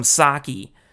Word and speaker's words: sake: As we Americans say, sake. I'd like to try some sake. Sake, as it's pronounced in Japanese.